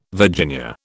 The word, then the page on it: Virginia: British English